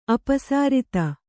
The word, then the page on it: ಅಪಸಾರಿತ: kannada